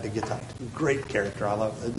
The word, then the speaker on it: Gittite: One of my favorites because his name rhymes with his home city, Ittai the Gittite.